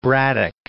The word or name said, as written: Braddock